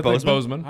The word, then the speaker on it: Boseman: And I feel, is it Boseman or Boseman? I'm thinking Boseman.